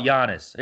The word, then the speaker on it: Giannis: Is he what do you think about Giannis? Here, I said his name right.